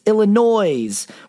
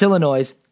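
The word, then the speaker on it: Illinoise: There is an E at the end of it because it's Illinois,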